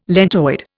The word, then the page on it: lentoid: Indian